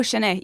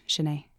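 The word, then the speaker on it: shen eh: you spell it S-H-U-N, is it? – For saying that's it, Shinae. – That's it. Oh, Shinae. Yeah, S-H-E-N space E-H, Shinae.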